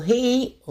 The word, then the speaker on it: гей: Смотрите, дело в том, что «гей» в норме произносится так же, как украинское фрикативное «г».